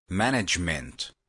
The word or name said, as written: management